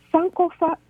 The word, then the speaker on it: Sankofa: Or Sankofa, okay? Sankofa, that's how you pronounce it.